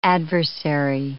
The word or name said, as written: adversary